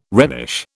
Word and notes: Rhenish: British English

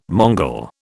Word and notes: Mongol: British English

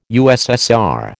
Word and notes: USSR: American English

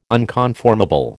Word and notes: unconformable: American English

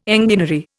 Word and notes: enginery: Indian

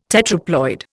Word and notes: tetraploid: Indian

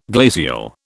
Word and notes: glacial: British English

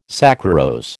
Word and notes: saccharose: American English

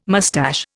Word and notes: mustache: Indian

